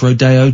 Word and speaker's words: Rodeo: Although it is pronounced Rodeo Drive, which is crazy. It's spelt Rodeo.